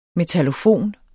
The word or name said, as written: metallofon